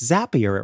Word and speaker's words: Zapier: And by the way, folks I know will sometimes pronounce this Zapier, but Zapier was a sponsor on the Twit Network. And so we got to speak to the folks there and they said, it's Zapier. It rhymes with happier.